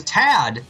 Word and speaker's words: TAD: This show is TAD, which rhymes with sad.